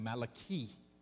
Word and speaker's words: Malachi: In the Hebrew, his name is pronounced Malachi.